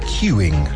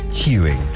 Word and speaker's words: queueing: Queuing. – Haciendo cola. Se escribe Q-U-E-U-E-I-N-G, pero se pronuncia... – Queuing.